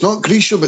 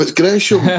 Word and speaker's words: Gresham: Scott always shouted, you're like, it's not Gresham, it's Gresham.